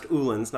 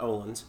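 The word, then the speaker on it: Öhlins: It's pronounced Uhlens, not Ohlens.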